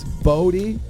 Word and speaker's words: Bodie: It's Bodie. Right? Am I saying that right? – Bodie, are we fucking serious? Your name is Bodie? – Yes. – Like Brody without the R.